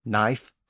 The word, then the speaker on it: knife: So as we've seen, the K at the front of words like night, knife, and not has disappeared.